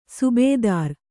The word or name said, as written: ಸುಬೇದಾರ